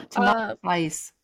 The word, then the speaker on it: Tamalpais: Tamal pies? – Tamaltize? Okay, great. – No, tamal pies. – One more time, you cut out. – Tamal pies? – Tamalapies? – Without the No? – tamal pies.